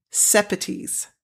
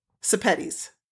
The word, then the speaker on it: Sepetys: And then she says, a good way to remember how to pronounce my last name is to say spaghettis, because it rhymes with spaghettis. Sepetys. There you go. – Spaghettis. – Sepetys.